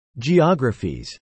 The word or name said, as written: geographies